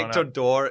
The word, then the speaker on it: Nectar d'Or: And then the much mispronounced in America, Nectar d'Or. – I will be pushing that. – Yeah. – It'll be Nectar d'Or from here. – Nectar d'Or is the correct name, which means golden liquid.